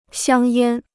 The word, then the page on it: 香烟: xiāng yān